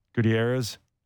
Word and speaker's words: Gutierrez: Gutierrez.